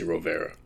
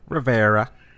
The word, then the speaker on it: Rovere: I mean, how would you say it if you were pretending to be Italian? – Rovere. – Yeah, there you go. Thank you. – Gotta add the extra garlic and olive oil. – Mm-hmm. Emphasis on the last part. Della Rovere.